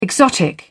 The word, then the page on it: exotic: British English